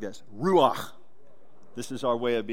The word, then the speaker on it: Ruach: This is a Hebrew word, Ruach. You got to say it like you're clearing your throat a little bit. Ruach.